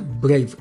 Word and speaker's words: Brave: Brave。はい。英単語の綴りは B、 R、 A、 V、 E でブレイブって読むんですが、実はそんなものがあるんですよね。